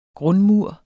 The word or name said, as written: grundmur